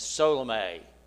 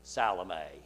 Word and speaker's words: Salome: And so if you're getting all confused on that, if you get an old King James and you look at it, you'll see the accent is on the L-O, Salome. And so that's why us old people call that way.